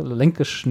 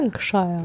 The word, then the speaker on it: Lancashire: Sie hatte, also hier Sarah Lancashire, Lankish, nee, wie schreibt man das denn aus? Das ist hier wieder so ein englisches, englisches Wort, was ich nicht aussprechen kann. – Lancashire.